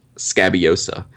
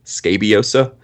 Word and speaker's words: Scabiosa: And it's got the combination of the Roaring Klingner Salix and the Roaring Klingner Scabiosa, which I got a pronunciation guide on Scabiosa. I've been saying Scabiosa. It's actually, which, you know, you don't really want to say it, but it's actually Scabiosa is the proper pronunciation.